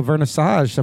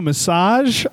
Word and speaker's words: vernissage: do a massage of the show. – Did I say it wrong? – No. – Vernissage.